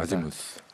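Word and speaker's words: Azymuth: 그래서 에지무스라고도 이렇게 약간 바꿔서 말하는데 보통 이제 아지무스라고 되는데요. 노마 윈스턴이라는 영국 재즈 보컬리스트가 있었던 재즈 트리오가 또 아지무스가 있습니다.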